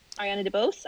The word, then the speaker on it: Ariana DeBose: Ariana DeBose, I think. – Yeah. – That's how you say her name.